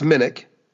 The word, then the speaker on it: Minich: Yeah, well, it's pronounced Minich. – Minich. – Like Minich Road in Fort Wayne.